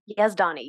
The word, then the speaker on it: Yazdani: Yazdani, you are very close.